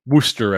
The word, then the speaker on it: Worcester: March 25th and 26th, he's at the Comedy Addict in Worcester. Worcester? – Worcester, I think is actually Worcester. Pronounced. Yeah.